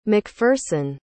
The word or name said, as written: MacPherson